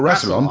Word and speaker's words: Rassilon: Well, Rassilon.